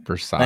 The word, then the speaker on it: Versace: Did I say it right? I'm Hispanic. – So, yeah. – I can't even Versace.